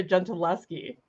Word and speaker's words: Gentileschi: Artemisia Gentilski. Gentilski. How do you say it? – Well, I mean, I'm not Italian, but the correct sort of a pronunciation, Artemisia Gentileschi.